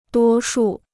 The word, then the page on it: 多数: duō shù